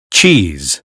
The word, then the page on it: cheese: American English